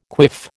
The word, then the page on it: quiff: American English